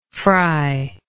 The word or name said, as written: fryable